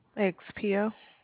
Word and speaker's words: XPO: X, how do you pronounce it? Expo Logic. – XPO.